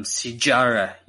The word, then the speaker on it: Sijara: We got Penny Kianzad taking on Sijara Eubanks. She likes to grapple, so I'll give her the Brazilian pronunciation with the J, the hard J.